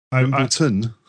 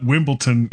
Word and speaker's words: Wimbledon: Well, badminton is spelled B-A-D-M-I-N-G-T-O-N. – Yeah, badminton. Pretty close. It's okay. Right. I still say Wimbledon. – Wimbledon? – I've been told that it's not Wimbledon, it's Wimbledon.